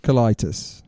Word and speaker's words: colitis: I can't pronounce colitis. – It. – Colitis.